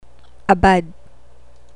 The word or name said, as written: abad